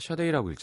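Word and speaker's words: Sade: 이걸원 샤데인데 이렇게 사 세이든지 사든지 이렇게 써놨을까? 그렇죠? 다 샤데이라고 읽잖아요. 근데 이게 샤가 아니잖아요.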